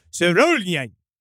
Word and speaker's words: Sørjulen: It was called Sørjulen. That definitely was what it was called. – Yeah. – That's how you pronounce it.